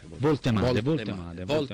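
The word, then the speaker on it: Woltemade: come si pronuncia? – Voltemade.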